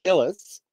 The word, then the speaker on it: Illes: I mispronounced her name since the beginning of time. While I was doing this research, I found out how to pronounce her name, which is Illis.